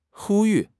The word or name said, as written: 呼吁